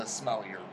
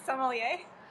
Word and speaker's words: sommelier: Oh, by the way, Miranda's also the smellier. – A smellier. – Is that how you spell that? Am I saying that? – Sommelier? – Yeah, smellier.